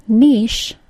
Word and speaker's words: niche: The word niche, spelled N-I-C-H-E, does not follow the pattern of the CH being pronounced as a CH sound, ch. It does follow the pattern that words which are spelled CH and are newly borrowed from French will be pronounced with an SH sound, sh.